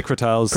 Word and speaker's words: crotales: How do you say them? Crotales? – I say crotales.